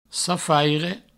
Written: safaire

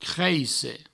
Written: creisse

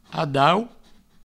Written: adaut

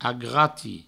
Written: agrati

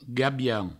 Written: gabian

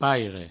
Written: paire

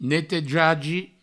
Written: netejagi

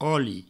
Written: oli

